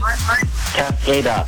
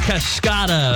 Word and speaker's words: Cascada: Cascada. – Cascada. Yes. Actually, it's pronounced Cascada.